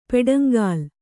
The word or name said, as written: ಪೆಡಂಗಾಲ್